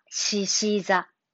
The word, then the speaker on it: 獅子座: えー、発音は獅子座。獅子座。獅子座です。